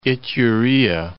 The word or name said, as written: Iturea